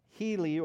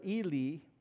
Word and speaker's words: Heli: And so they would argue that it's possible that Heli or Eli, depending on your pronunciation, adopted Joseph as his heir.